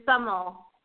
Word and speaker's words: Samel: But right now we'd like to bring in Jackie, and Jackie, if I'm pronouncing your last name correct, is it Samel? – It's Samel.